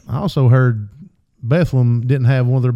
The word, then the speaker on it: Bethlehem: Pikeville versus Bethlehem, probably. Bethlehem. It's not Bethlehem, even though it's spelled that way. They pronounce it Bethlehem. – All right. You say Bethlehem, you sound like an idiot.